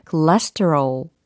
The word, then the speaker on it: cholesterol: 아, 콜레스테롤이라는 단어의 발음이 cholesterol cholesterol 이렇게 해야 되는구나라는 것도 알아가실 수 있겠죠.